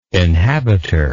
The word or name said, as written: inhabiter